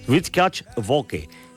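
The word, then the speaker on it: Wirtschaftswoche: Me he ido al diario que ha sacado la historia, que es el periódico alemán, a ver si lo digo bien, Rutgers Woche. Perfecto.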